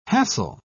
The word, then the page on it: hassel: British English